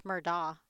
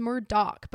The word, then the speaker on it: Murdaugh: I think it's Murdaugh. – No, it's Murdoch, but it's spelled Murdaugh. Like, that's the weird thing about his name, is it's spelled Alex Murdaugh, but it's Alec Murdoch.